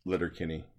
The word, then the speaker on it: Letterkenny: I'm a huge – I have Letterkenny.